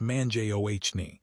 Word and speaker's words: Mangione: By bringing in veterans like Fred Mangione, pronounced Manjohni, to run operations like Rutgers Scarlet Knight Enterprises, universities are treating revenue generation as a specialized executive function.